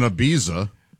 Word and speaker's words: Ibiza: She did it in Ibiza. – Oh, nice. – Huh. – It's Ibiza. – It's Ibiza. Ibiza, by the way. – Come on with that. – The Z is pronounced TH.